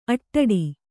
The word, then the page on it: ಆಟಿಡು: āṭiḍu